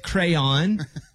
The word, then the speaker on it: crayon: You said crown, but it's crayon.